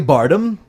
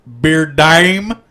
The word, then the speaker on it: Bardem: Javier Bardem from No Country for Old Men. – I think it's pronounced Beard Dime. – No, it's not. – No, it certainly is not.